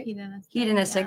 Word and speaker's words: hedonistic: Hedonistic. Did I say that right? – Hedonistic. – Yeah. Thanks. I always say that wrong.